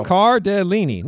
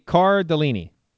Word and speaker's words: Cardellini: Cardinelli, right? – What? Car. Linda Cardinelli. Tom mispronounces everything. Yeah. – So. – Cardellini. No, no. I googled how to pronounce that name, and it gave me Cardellini.